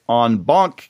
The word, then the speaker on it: en banc: And she's going to be talking about a Fourth Circuit en banc case, and that's the correct pronunciation, as you, you know from the last couple episodes.